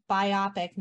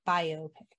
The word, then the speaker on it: biopic: Using the compound stress rule, the word is pronounced biopic, not biopic.